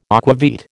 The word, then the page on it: aquavit: American English